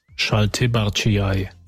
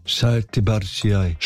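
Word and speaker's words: Šaltibarščiai: Schaltibarciai. – Schaltibarciai. – Schaltibarciai. – Okay, litauische Speise, Klammer auf, kalte Suppe mit rote Beete und Kartoffeln. Hier. – Schaltibarciai.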